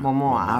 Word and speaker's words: Momoa: Se llama Jason Monoa. Ah, y yo he dicho Momoa, ¿no? Bueno, Momo, Momoa, perdón. – Momoa, ah, vale, pues lo he dicho bien.